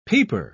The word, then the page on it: peeper: British English